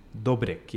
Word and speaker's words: Dobrik: Dobrik, yeah.